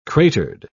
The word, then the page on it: cratered: British English